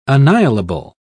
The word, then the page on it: annihilable: British English